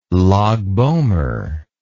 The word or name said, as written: Lag b'Omer